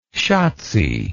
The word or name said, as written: schatzi